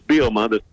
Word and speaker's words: Bielema: So I asked him, thinking it was three syllables. Brett? – It's pretty close. It's Bielema. – It's Bielema. He said it. I'm going with it.